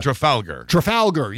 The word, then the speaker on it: Trafalgar: Well, he talks about Trafalgar, and I don't know if that's how you pronounce it, but Trafalgar. – Yeah, there you go.